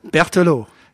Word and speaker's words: Berteloot: I'm Mickaël Berteloot and not Berteloot or something else. – Berteloot. – Yes. – Okay, so for an American, how would I pronounce that? Berteloot?